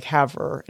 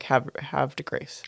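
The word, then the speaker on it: Havre de Grace: They said I was putting too much emphasis on the R. – You were saying like Haver, and it's more like have de grace.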